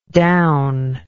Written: downlike

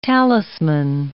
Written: talisman

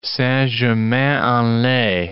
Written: Saint-Germain-en-Laye

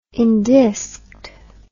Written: endisked

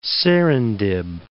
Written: Serendib

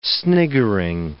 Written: sniggering

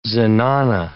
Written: zenana

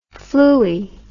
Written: fluey

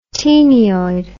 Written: taenioid

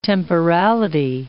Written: temporality

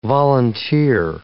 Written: volunteer

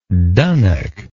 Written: dunnock

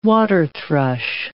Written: waterthrush

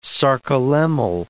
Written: sarcolemmal

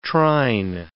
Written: trine

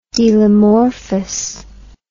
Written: delomorphous

